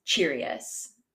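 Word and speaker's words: Chereas: There was one guard in particular that expressed to one of the prefects what he wanted to do, and his name was Cherius. It's C-H-E-R-E-A-S, and I actually did remember to listen to a pronunciation guide for that one.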